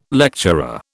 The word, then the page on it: lecturer: British English